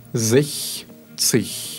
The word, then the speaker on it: sechzig: Также 60. Так же, как 16 ранее, угу. – у нас там выпадает буква S, в результате чего читается мягкой Х. Зех, цих.